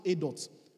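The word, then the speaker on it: edut: It's E-D-U-T, but it's pronounced edot.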